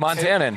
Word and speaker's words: Montanan: Is Montanan the correct? – Montanan.